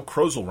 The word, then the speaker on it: Krozel: Saturday, March 20th, by Joe Krozel, rhymes with puzzle.